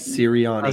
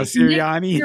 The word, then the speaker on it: Sirianni: Sirianni. – Sirianni.